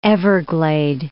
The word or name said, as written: everglade